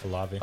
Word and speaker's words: Olave: 18, the Saints picking up Chris Olave. Is that how you pronounce that? Olave? – Olave. – Olave. Is it Olave? – Olave.